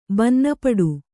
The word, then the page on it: ಭಿನ್ನಪಾಠ: bhinna pāṭha